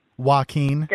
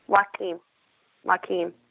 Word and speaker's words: Joaquin: Joaquin. – Sounds Indian, doesn't it? – Yeah, it's Spanish, actually. It's maybe if it helps you to remember Joaquin Phoenix, the actor.